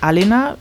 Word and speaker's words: Alena: Also, um die Frage äh, zu beantworten, ich heiße tatsächlich Alena äh, mit Betonung auf dem ersten A. Till macht es also vollkommen korrekt. Ähm, es ist im Grunde so wie bei Elena.